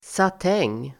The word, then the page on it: satäng: [sat'eng:]